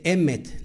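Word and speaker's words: emet (אמת): Y dice emet, la palabra verdad en el hebreo es emet. Fíjate lo, que es eh, la raíz hebrea, porque es importante meternos a la raíz hebrea. Es emet, eso es, eso es verdad.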